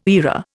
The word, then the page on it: era: Indian